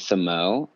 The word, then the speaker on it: Samoht: A new artist I've been listening to is, his name is Samo, and it's spelled S-A-M-O-H-T.